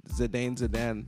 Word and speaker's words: Zidane: And five is I say Zidane, but I'm pretty sure I'm saying that wrong. I think it's Zidane. – Zinedine Zidane.